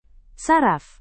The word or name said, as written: saraf